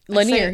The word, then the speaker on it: Lanier: It's Lanier, right? – Lanier, yeah.